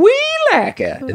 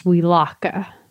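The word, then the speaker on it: Welaka: And that's how you have to pronounce it. – Actually, I think if you're from there, it's actually pronounced Weelaka. But Weelaka. – Weelaka, yeah. – But if you're me, we pronounce it Weelaka.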